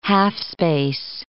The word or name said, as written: half-space